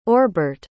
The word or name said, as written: orbert